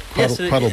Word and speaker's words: Quattlebaum: As I was brought up, it was Quattlebaum, but I've been here for a while. – Well, Baum. – But it's spelled Baum, as in the way Germans would pronounce Baum.